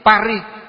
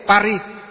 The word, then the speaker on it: Paris: Ví dụ như Paris, thành phố Paris của Pháp, chữ R, R phát âm gì? Pa. R, R, Paris. Hơi uốn lưới nhỉ? Paris.